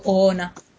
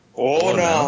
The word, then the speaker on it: Oona: Yo le digo Oona. – Oona. Oona. – Tal cual se lee.